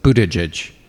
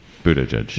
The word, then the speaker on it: Buttigieg: Yeah, just think boot, edge. The word edge pronounced twice in a row with emphasis on boot. So Buttigieg. – Buttigieg.